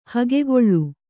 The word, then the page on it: ಹೆಗ್ಗುಳ್ಳ: kannada